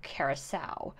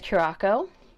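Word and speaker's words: curaçao: And the topical cocktail is simply the Ukrainian flag, which is one part banana liqueur and one part blue curacao, which I have apparently been mispronouncing as curaco.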